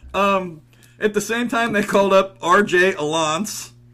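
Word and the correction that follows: Alaniz: Am I pronouncing his name right? – I think it's Alanes. – Alanes.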